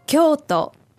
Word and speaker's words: Kyoto: Kyoto.